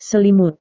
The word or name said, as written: selimut